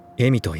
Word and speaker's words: 笑: 鹿俣の名前は笑うと書いてエミと言うんだ。 – 私が生まれた時山が笑ってたんだって。お父さんの言うことはいつもわけわかんないよ。エミなんて名前嫌い。